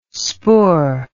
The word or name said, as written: spoor